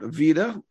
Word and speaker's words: Vida: He and his wife, Emily, serve as lead pastors of Resurrection Life in Holland, Michigan, and also Iglesia, and I may be mispronouncing it, Vida. Is that close? – Yep.